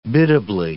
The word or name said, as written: biddably